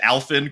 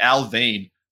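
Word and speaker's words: Alfvén: It's like, because it, to me, I'd say the Alphen critical surface, but it's like, has all these weird un-American looking, I'm too ignorant to know what they look like, what how to pronounce it. So I looked it up, it's like Alvane.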